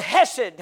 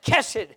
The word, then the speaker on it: hesed: It's the word hesed. And some folks pronounce it kesed.